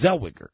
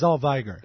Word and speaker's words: Zellweger: It's Zellweger. That's who it is. – I'm sorry, that's Zellweger. – Zellweger! – Zellweger, okay.